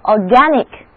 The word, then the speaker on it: organic: 如果我们想得到 organ 这个单词的形容词形式，只要在它的后面加上 IC 这个形容词后缀就可以了，不过这个时候字母 A 的读音就变成了大口的梅花 A, 整个单词读作 organic,